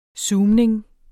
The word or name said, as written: zoomning